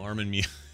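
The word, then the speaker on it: Armin Meiwes: Notorious cannibal Amarin Mewis, I think is how you say it. – Armin? – What did I say? – Armin Muse. – No, Armin Muse. So it's nothing like what I said then.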